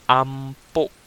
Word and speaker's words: ampo: So a prayer in Cebuano is called ampo and panalangin.